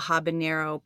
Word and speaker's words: habanero: And for me, I would not enjoy watching people eat a habanero pepper.